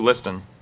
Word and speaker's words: listen: that it may benefit those who listen. Listen. There's a silent T in there.